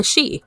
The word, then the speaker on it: Sidhe: And the other really big popular group of fae are the Shi, which is spelled S-I-D-H-E, not S-H-E-E, but it's pronounced Shi, which the Shi has even become synonymous with the term fairy.